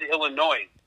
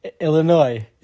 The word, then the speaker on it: Illinois: People who pronounce letter S in Illinois. Because that's your hometown, right? – Birth state, but this is tricky because I feel like you said Illinois. – Illinois.